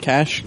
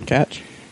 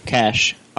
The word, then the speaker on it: cache: How do you say that? Cash? Catch? – Cash. – All right, I always say catch. – It's cash. It's C-A-C-H-E.